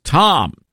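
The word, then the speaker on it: Thom: Why does your senator spell his name with an H? It's Tom. We don't need the H. The H is silent.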